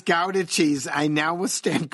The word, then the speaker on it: Gouda: She would like me to convey to you that the Dutch cheese is not pronounced Gouda. She says that may be the way it's said in backwoods Wisconsin, but when you and Tom are hiking the trails in your raccoon skin hats, wielding your Bowie knives, she has a vivid and fertile imagination. The correct pronunciation is Gouda.